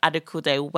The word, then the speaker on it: Adekunle: How do you say it? Silas Adekunle. – Silas. – Silas Adekunle. Well done.